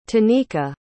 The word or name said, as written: tenika